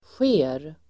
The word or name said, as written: sker